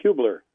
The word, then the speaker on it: Hubler: And Mr. Hubler, am I pronouncing your name correctly? – It's pronounced Hubler.